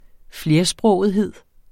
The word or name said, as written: flersprogethed